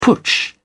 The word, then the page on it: putsch: British English